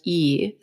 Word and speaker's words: yi: known as yi. It's spelled Y-I, which has to do with the mind.